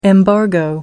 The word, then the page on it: embargo: American English